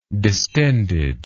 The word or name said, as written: distended